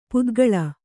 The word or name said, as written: ಪಿತ್ತಕಲ್ಲು